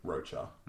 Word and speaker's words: De La Rocha: Well, yeah, again, like, it wasn't someone sitting there with Zach going, hey, I'm here with Zach De La Rocha, or Zach... saying it himself, but like definitely in the video they pronounce it Rocha.